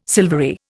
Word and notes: silvery: Indian

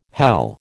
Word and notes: hell: American English

